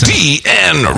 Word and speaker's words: CDN: When you say it, you pronounce every letter. – Yeah, it has to be a little more prominent because it's CDN Records. – So it doesn't sound like CD and Records.